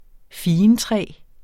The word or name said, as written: figentræ